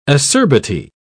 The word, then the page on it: acerbity: British English